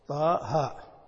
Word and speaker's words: طه: طه حتى ولو كانت علما على شخص فإنهم يكتبونها هكذا ولا تكتب، ولا تكتب طه مع أن هذا نطقها طه، يكتبونها هكذا، وهذا من التأثر بكتابة المصحف، لكن مما أقر وثبت في الإملاء.